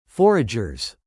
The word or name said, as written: foragers